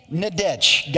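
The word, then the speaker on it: Nidetch: Did I pronounce that right? No? Okay. I've been pronouncing it. I got to say it like five times. Nadech. Okay. Nadech. Got it.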